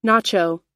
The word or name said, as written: nacho